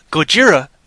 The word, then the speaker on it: Gojira: Gojira is the Japanese pronunciation of Godzilla.